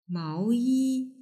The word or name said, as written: maoyi